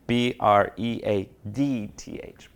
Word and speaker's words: breadth: Breath, the t- can't pronounce it always. B R E A D T H, breadth.